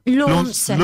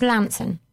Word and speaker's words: Launceston: How do you pronounce Lanton? – How do I pronounce what? – Launceston. – Launceston. – There you go. Yeah. It's not. It's Lanton.